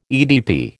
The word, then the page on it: EDP: American English